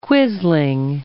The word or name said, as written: quisling